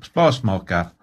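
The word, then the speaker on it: spasmaker: Nej, S-P-A-S. – Ja. – M-A-K-E-R. Spasmakar, nej det ska uttalas på norsk, spasmakar.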